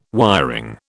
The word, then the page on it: wiring: British English